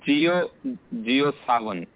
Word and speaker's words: JioSaavn: Is J-I-O-S-A-A-V-N. Is it Jiosavan? – No, it's Jiosavan. – Jiosavan, okay. – Yeah. – And the other one is G-A-A-N-A.